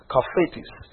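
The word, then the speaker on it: chaphets: You may pronounce as kafetis, but I'll spell it to you so you don't have a problem with it. C-H-A-P-H-E-T-S. C-H-A-P-H-E-T-S. That's a Hebrew word. Pronounced as kafetis.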